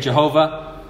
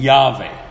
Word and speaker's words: Yahweh: It's the same name that is butchered and we say Jehovah, but that's not a good pronunciation at all. So it's the name Yahweh.